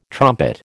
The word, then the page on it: trumpet: American English